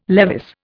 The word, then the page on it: Levis: Indian